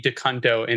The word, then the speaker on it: DeCunto: And I did the pronunciation for it because I'm like, there's no way There's no way it can be DeCunto, and it absolutely is.